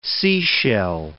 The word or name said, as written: seashell